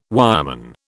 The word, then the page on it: wireman: British English